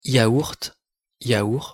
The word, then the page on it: yaourt: French